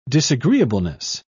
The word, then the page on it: disagreeableness: British English